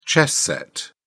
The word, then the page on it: chess set: British English